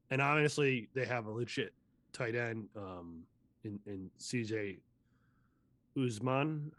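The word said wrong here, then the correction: Uzomah: I think is how it's pronounced. – Uzama. – I mean, Uzama, thank you.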